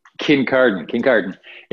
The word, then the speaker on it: Kincardine: I was, yeah, in a small town called Kincardine. – What is it called? I tried to pronounce it and I couldn't for the life of me. – Kincardine.